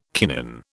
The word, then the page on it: kinin: British English